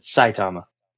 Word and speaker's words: Saitama: You pronounce it Saitama.